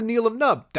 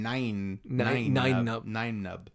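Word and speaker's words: Nien Nunb: What's a Neelam Nub? That's an understandable question. – Are you adding an L in there? – It's like N-I-E-N, nine nub. I've always heard it pronounced nine.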